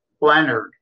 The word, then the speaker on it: Lenard: You you know, with my last name, L E N A R D. Leonard. People always want to call pronounce it Leonard.